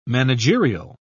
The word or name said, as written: managerial